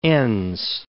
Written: Enns

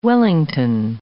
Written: Wellington

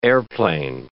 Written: airplane

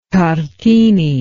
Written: tartini

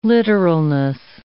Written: literalness

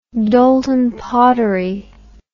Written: Doulton pottery

